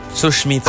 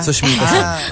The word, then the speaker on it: Sushmita Sen: It's hard to pronounce her name, huh? Susmita Sen. – Susmita Sen. – Sushmita. – Ah, ganun ba? – Sushmita Sen. – Ah, ganun ba? – I have a problem with... – That's Chico feigning innocence. – I have a problem with the SH.